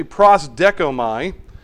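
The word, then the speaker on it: prosdekomai (προσδέχομαι): And I'm trying to find the actual word so I pronounce it correctly. It's actually prosdekomai. The word prosdekomai can mean either received or wait for or anticipate or accept or look for.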